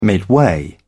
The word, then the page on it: midway: British English